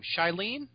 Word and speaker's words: Shailene: Shailene? – Yes.